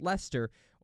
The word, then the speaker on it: Leicester: The English city Leicester is pronounced Lester, or I've heard it Leicester, but Lester, like Lester Flatt.